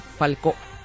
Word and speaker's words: Falcó: Falcó. – ¿Falcó? – Así mismo. – ¿Acento en la O? – Acento en la O.